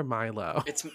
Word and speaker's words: Milo: Is it Milo or Milo? – It's Milo James Thatch. That's like how they say it in the movie.